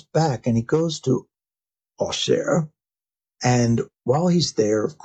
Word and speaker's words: Auxerre: What is Aughshere? A-U-X-E-R-R-E, right? So that is the French pronunciation.